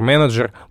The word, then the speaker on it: менеджер: Можно на самом деле пойти еще дальше, и в слове, например, менеджер буква Е читается не как в слове лес, а как «э».